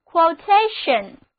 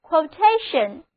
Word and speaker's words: quotation: 好了，炒完了股票，我们来看别的单词，我们在 quote 后面把一去掉，加上 ation 的名词后缀，那么 quotation， quotation， quotation，重音后移了，单词就读作 quotation，就是 quote 的名词，引文、报价、行情。